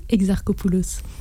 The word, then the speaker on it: Exarchopoulos: Exarchopoulos.